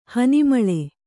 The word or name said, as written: ಹನಿಮಳೆ